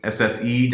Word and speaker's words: SSE-C: Option A, SSE-C. Did I say that right? – That's correct. – Cool, not SSEC.